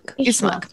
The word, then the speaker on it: Ismark: Okay, then Sevier, after dinner, is going to get, how do you say the man's name? Ismark? – Ismark.